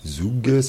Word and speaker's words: Hougues: Cette structure qui s'appelle Terre des Ougues, ça s'écrit H-O-U-G-U-E-S.